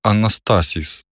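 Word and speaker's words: анастасіс (ἀνάστασις): Для позначення феномену воскресіння в Євангелії застосовується слово, яке читається як «анастасіс». Усамітнившись з учнями на високій горі, Ісус пояснив їм, що таке воскресіння, і розповів про механізм його здійснення.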